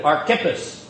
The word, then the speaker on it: Archippus: Get the accent on the second syllable. Let's all say it together. Archippus. There you go. It's like Hippus. Archippus.